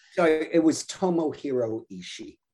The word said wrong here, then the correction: Ishii: Tom, no, uh, Tomohiro Ishii. So I miss. I, I mispronounced the name.